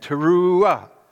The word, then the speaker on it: teruah: It's called a teruah, with the accent on the end, teruah.